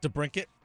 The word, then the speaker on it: DeBrincat: DeBrincat. – DeBrincat is such a cooler name. Like, it sounds like you could be a Thundercat. If you were DeBrincat. Like Alex DeBrincat, grab your sword, let's go, you know?